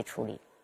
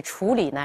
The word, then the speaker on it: 处理: 嗯，这个赏声的处理没有问题，但是第二个词在读的时候，冷处理处理不是冷处理啊，因为处处它是一个多音字，那么什么时候读处啊？做名词的时候，处所，哎，住处，那么在这里处理呢是一个动词，它要读作三声。